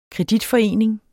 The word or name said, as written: KREDITFORENINGEN